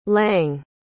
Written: lang

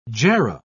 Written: jarrah

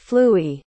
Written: flooey

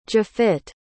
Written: jafit

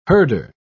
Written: herder

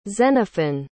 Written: xenophon

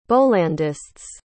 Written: bollandists

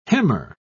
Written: hemmer

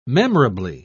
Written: memorably

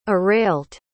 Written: Arailt

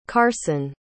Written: carson

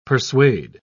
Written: persuade

